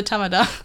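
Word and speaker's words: Tamada (他妈的): Tamada.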